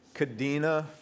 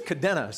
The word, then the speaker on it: Kadena: And just a little tidbit for you you know, you all say it wrong when you say Kadena. Do you know it's actually pronounced? Thank you. There it is. That's, there's, that's the, uh, uh yeah, Lisa nailed it. Kadena. It's basically Kadena. So every time, it's funny, every time Americans hear somebody pronounce it, they're like, that's, you're wrong. It's Kadena. But actually, it is Cadena.